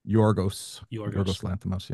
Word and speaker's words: Yorgos Lanthimos: directed by Yorgos Lanthimos. Am I pronouncing his name right? – Yorgos Lanthimos, yeah.